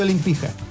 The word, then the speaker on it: Olimpija: Es que se escribe Olimpija, pero hacen chiste, porque es Olimpija. – Claro. – Pero se escribe Olimpija. – Pero, sí, pero la J es riendo. Como Djokovic.